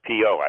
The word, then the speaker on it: Piot: Der Spiegel interviewed the discoverer, Peter Piot, I believe it's pronounced, P-I-O-T.